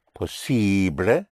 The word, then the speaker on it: posible: Because ibly in Spanish is pronounced ible, because the letter I in Spanish is always pronounced E. And ably in Spanish is pronounced able, because the letter A in Spanish is always pronounced A. So we have ible and able. So if you want to say possible would be posible, and probable would be probable.